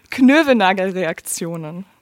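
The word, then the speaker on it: Knoevenagel: Das wäre Chemische Evolution antiviraler Wirkstoffe gegen Enterovirus D68 durch Proteintemplat gesteuerte Knövenagerreaktion. – Ach, so spricht man das aus auf. Kannst du nochmal das letzte Wort sagen? Knövelnagelreaktionen. Knövelnagel, Knö, Knövelnagelreaktionen. genau.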